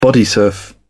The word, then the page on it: bodysurf: British English